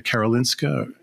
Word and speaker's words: Karolinska: And then what led you to, is it, am I pronouncing it correctly, the Karolinska Institute in Stockholm? – Yeah.